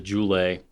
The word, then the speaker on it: Goulet: Their only you know, stipulation is instead of the Goulet Pen Company is still going to be spelled the same, but it's going to be pronounced the Joulet Pen Company because their G is a J.